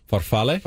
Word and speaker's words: farfalle: How do you say it? – Farfalle? – Farfalle.